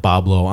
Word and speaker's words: Bois Blanc: We had a ferry over to it's spelled B-O-I-S-B-L-A-N-C Island. Bois Blanc, but locals call it Bablo Island.